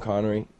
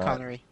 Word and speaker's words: Connery: I watched all the Bond movies first with Pierce Brosnan, and then I, I watched a few with Sean Connery. Is that am I saying that right? I keep on thinking I'm That's not. Connery.